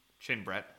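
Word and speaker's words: Chin-Bret: So Chin Brett spelled like Chin, so C H I N dash B R E T, Chin Brett.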